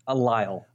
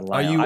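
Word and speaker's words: Lisle: Are you sure you're pronouncing it correctly? Because I'm looking at it, and it's L-I-S-L-E. You sure you got the pronunciation correct? – Yes. Okay. The S is silent, yeah.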